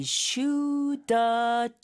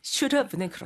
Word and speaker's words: should have: 그러면은 이게 무슨 slow audio로 했을 때 slow video motion이 아니라 slow audio 하면은 I shoulda charged it. – Exactly. – 그렇죠. – I shoulda charged it. – I shoulda charged it. – 네. – should have는 그렇게 빠르게 발음하면 그렇습니다.